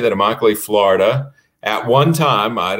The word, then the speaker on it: Immokalee: So it's Immokalee. That is a word from the Seminole Indian language, and it means my home. You can always tell the name, pronounce the name of an Indian word because every letter is pronounced. Nice. Every letter is articulated. So there's nothing that remains silent.